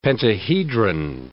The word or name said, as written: pentahedron